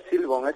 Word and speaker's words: Silbón: Perdón, ¿es Silbón o Silbón? – Es Silbón, no lleva tilde.